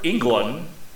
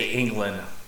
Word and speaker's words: Englund: E-N-G-L-U-N-D. – Yeah, that's how it's spelled. – Yeah, I know, but some people have a hard time pronouncing it right. – I know, they say England.